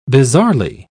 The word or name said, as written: bizarrely